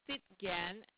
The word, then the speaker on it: Stitgen: Last name is Stitgen, which is S-T-I-T-G-E-N. And then the first name is Gail, G-A-I-L.